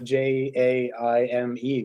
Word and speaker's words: Jaime: There it's it's Jaime, but J A I M E, which would be Jaime.